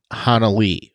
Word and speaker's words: Hanelle: And if you're wondering on that pronunciation, her name is spelled Hanelle, like H-A-N-E-L-L-E, but I've heard her in several different podcasts and interviews where it's specifically pronounced Hanalee.